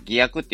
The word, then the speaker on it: 偽薬: 偽の薬って書いて偽薬って読むんですけども、その偽薬と新薬を与えて、その効果を検証したら、その偽薬を与えられた方も症状が改善したっていうんですね。